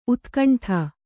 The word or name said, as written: ಊದುಗಂಡಿ